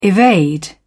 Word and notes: evade: British English

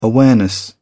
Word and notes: awareness: British English

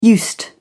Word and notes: used: British English

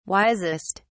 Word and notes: wisest: British English

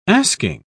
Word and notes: asking: British English

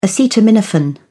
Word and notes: acetaminophen: British English

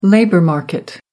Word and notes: labour market: American English

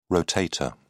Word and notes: rotator: British English

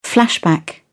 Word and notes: flashback: British English

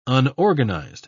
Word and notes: unorganized: British English

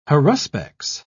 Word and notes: haruspex: British English

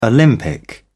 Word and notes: olympic: British English

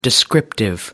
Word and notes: descriptive: American English

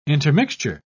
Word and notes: intermixture: British English

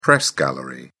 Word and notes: press gallery: British English

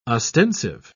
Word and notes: ostensive: British English

